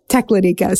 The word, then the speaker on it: Teklanika: I had trouble figuring out how to pronounce this river. Just so you know, I ended up calling multiple Alaskans, and they said, Teklanika. So we're going with that, even though the internet sometimes says otherwise.